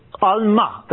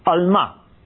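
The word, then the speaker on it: alma (עלמה): Now look, a young woman, an alma, that's how you say it in Hebrew, alma.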